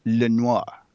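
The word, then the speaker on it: L.A. Noire: You said it wrong. It's Le Noir. All right. It's French.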